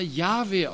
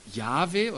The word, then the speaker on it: Yahweh: Manche sagen, dass dieser Name Yahweh ausgesprochen wird.